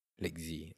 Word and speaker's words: Lekzy: So you can just call me Lekzy, like L-E-K-Z-Y. Sounds like lazy, but with a k, like when you're choking.